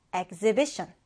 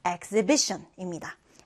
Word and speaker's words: exhibition: 우리 2강세 그리고 1강세 표시에서요. exhibition, exhibition입니다.